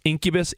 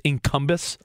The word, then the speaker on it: Incubus: Didn't she say she pronounced incubus, incumbus? – Yeah, it bothered me.